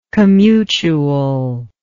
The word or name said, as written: commutuality